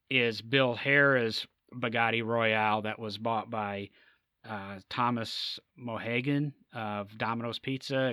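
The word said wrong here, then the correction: Monaghan: Excuse me if I mispronounced his name. – Tom Monahan. – I know. Monahan, yes. Okay.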